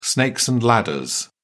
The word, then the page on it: snakes and ladders: British English